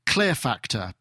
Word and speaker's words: Klirrfacktor: That's by somebody called, now if I can pronounce, Clear Factor, spelt KLIRRFACKTOR, and it's called Crouch Step.